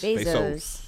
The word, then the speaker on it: Bezos: Bezos.